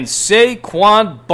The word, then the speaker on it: Saquon: And can Saquon Barkley...